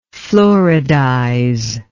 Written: fluoridization

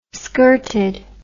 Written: skirted

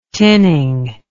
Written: tinning

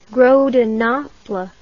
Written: gros de Naples

